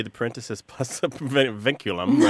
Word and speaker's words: vinculum: I'm going to mispronounce this, but it's a... – Vinculum? – Vinculum, yeah. It's a great Scrabble word, by the way. V-I-N-C-U-L-U-M.